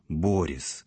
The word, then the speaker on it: Борис: Собственно, его личное имя тут произносится с ударением на первый слог – «Борис».